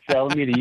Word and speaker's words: Salamita: It's like we need the nuclear vessels in Alameda. Salamita, is the right way to say it? – Yeah, that's correct. Salamita, you got it.